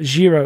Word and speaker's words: Jiro: If people haven't seen it, it's called Zero. It's spelled J-I-R-O, Zero Dreams of Sushi.